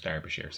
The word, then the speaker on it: Derbyshire: Dar- pronounced Derbyshire, sorry.